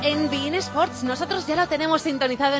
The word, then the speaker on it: beIN Sports: Gracias a Bein Sports, que sí, se pronuncia así, Bein Sports, el canal premium de fútbol internacional.